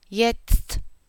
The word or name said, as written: jetzt